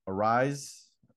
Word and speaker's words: Arraez: The players to watch, of course, for us will be Byron Buxton and Luis Arraez.